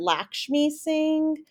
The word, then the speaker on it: Lakshmi Singh: And she pronounces it Lakshmi Singh. Mm. And people get upset with her. And she's like, that's how we pronounce it in Guyana.